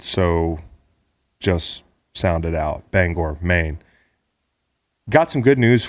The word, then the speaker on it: Bangor: but the folks in Bangor don't care for it when you say Bangor, Maine. It's Bangor, Maine.